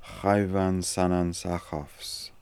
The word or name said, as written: khaivansanansakoffs